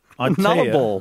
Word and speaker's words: Nullarbor: Nullarbor. – Nullarbor. – I don't know the correct... What is the correct pronunciation? – Pronunciation. Yeah. Nullarbor. – You sure? – Yes. It's not a ball.